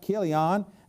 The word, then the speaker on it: Chilion: But Elimelech and Naomi, Elimelech being the father and Naomi being the wife, and Chilion, actually, the Bible actually says it's supposed to be pronounced Chilion, and Mahlon were the two sons of Elimelech and Naomi.